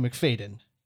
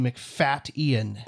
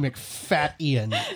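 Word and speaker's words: Macfadyen: And there's a funny thing about that, which is that we always thought it was Matthew McFadden. It is not. It is Matthew McFat-Ian. It is Matthew McFat-Ian. You're not so if you're ever Ian. Wondering, if you're ever wondering how to pronounce the man's name, there is a glottal stop in it.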